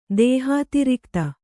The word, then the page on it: ದೇಹಾತಿರಿಕ್ತ: dēhātirikta